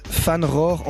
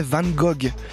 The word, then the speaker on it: Van Gogh: prononciation néerlandaise Van Rohr, en français Van Gogh.